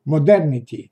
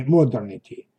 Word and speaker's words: modernity: Но если вы добавите окончание к слову «modern» Э, модерн, современный, то это окончание потянет за собой и ударение. Модерн, однако modernity, а не modernity.